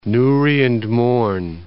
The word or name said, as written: Newry and Mourne